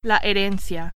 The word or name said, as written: herencia